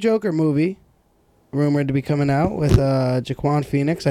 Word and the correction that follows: Joaquin: I think that's how you pronounce his name, Jaquan. – I think it's Joaquin. – Joaquin? Yeah. Oh, it is.